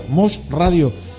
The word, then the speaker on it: Mosh Radio: Hoy en una nueva casa, Mosh Radio, así se pronuncia, Mosh Radio.